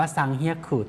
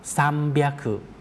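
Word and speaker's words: ซัม เบีย คุ (三百): 300 เนี่ยไม่ได้ออกเสียงว่าซังเฮียคุแต่จะออกเสียงว่าซัมเบียคุ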